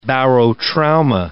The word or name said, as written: barotrauma